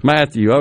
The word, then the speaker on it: Mathieu: Mathieu, okay.